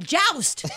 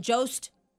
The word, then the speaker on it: Jost: God, I always say it wrong. I'm like, Joust. I can just see them seething when I do that. Anyway, Scarlett Johansson and Colin Jost.